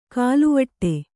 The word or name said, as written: ಖಲ್ವಾಟ